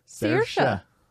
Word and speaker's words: Saoirse: Well, let's not forget about Saoirse Ronan. Everybody says her name wrong, too. – What is it? – Saoirse. – And what does everybody else say? – Saoirse.